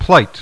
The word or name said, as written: plight